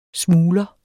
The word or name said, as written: smugler